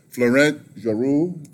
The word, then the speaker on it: Florent Geroux: My next guest is the leading purse-winning jockey at the fairgrounds this meet, Florent Geroux.